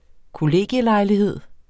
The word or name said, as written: kollegielejlighed